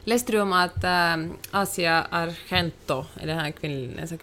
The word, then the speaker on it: Asia Argento: Vänta, kan, du hade ett klipp där med, där vi fick höra uttalet. Du sa alltså, du, du, du googlade till och med upp det för du var osäker på hur det skulle uttalas. – Men hon sa så här, Asia Argento.